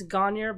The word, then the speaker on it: Gagnon: It's Gagner, but it's spelled G-A-G-N-O-N. So there's not an R in it, which was makes it confusing. So it's French. So technically it's Gagnon,